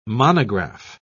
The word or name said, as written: monograph